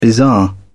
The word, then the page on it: bizarre: British English